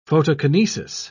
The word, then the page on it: photokinesis: British English